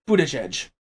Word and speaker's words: Buttigieg: Well, he doesn't want to feature his last name, which I'm pretty sure is pronounced Buttigieg.